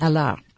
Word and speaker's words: Allard: The French way is Allard. The D is silent. – Allard.